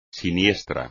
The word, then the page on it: siniestro: espagnol